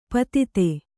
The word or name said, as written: ಪಾಥೋದಿ